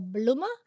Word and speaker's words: blomma: And I've also made lots of the blomma or blomma tank, as it's actually pronounced in Sweden.